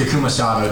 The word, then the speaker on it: Takuma Sato: Um, so I'm going to go with Takuma, Sato, Takuma Sato to win uh, the Indy 500.